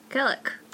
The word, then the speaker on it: Kalik: You went to Kellick? – Is that his name? – Duncan Town, Kellick. – How do you spell that? – K-A-L-I-K. – Kellick.